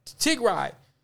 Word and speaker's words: Tigray: And then you over here in Tigray, how do you say it? – Tigray.